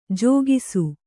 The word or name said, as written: ಜಾಗಿಸು